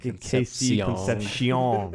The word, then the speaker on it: Concepcion: Kevin Conception is one of my favorite ones. Maybe it's pronounced Concepcion. I remember hearing this stuff. – KC Concepcion.